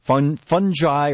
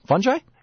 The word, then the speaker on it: fungi: Fungi, or how do you say that, Paul? Fungi? – You can say fungi or fungi. That's Or fungi. I've heard both, and I never knew. So both are right.